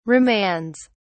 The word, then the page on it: remands: British English